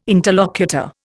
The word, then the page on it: interlocutor: Indian